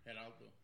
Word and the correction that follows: Gerardo: Yeah, if you don't know how to roll your R's, it's Gerardo, which is just a cool name.